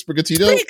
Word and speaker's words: Sprigatito: Sprigatito, I guess. Is that what it is? Sprigatito? – Yeah, that's what it is.